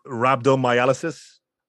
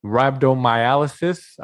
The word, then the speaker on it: rhabdomyolysis: Rhabdomyolysis. – Rhabdomyolysis.